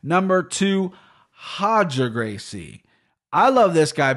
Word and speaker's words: Roger: I love that his name's Roger, and they pronounce it Hodger as well.